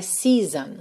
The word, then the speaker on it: season: Nesse season que eu também pintei aqui de verde só porque a escrita não te leva para a pronúncia correta, né? Nós temos S-E-A-S-O-N e a pronúncia é season, season, ok?